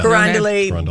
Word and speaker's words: Carondelet: Carondelet. Is that how you say it? – Carondelet. Sure. Yeah. Carondelet.